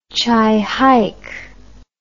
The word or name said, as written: chihike